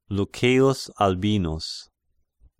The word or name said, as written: Lucceius Albinus